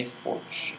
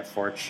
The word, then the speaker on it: Forte: Henny Forch, I believe is how you pronounce Henny, it. – Yeah, Forch, you're right.